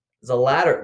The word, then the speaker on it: Zalatoris: Zalatoris. What is it? – Zalatoris. – Zalatoris. Jeez. Botched that one.